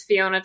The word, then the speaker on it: Fiona: Um, but the the title of the video series is Fiona to Kona. It is the first time my name has actually rhymed with anything helpful.